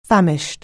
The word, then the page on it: famished: anglais